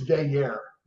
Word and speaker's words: Veyer: But anyway, I think it's Veyer, is how he pronounces it, but his dad pronounces it like we do, Veyu.